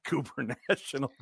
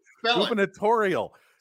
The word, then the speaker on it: gubernatorial: It is not Cooper National. – Yes, it is. – It is Spell it. – Gubernatorial.